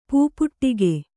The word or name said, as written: ಪಾಪಟಿಕೆ